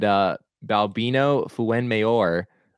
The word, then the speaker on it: Balbino Fuenmayor: Oh, I'm looking at his baseball reference page, and Balbino Fuenmayor, which I'm probably entirely butchering his name. – I don't think you are. I'm digging the way you're pronouncing it.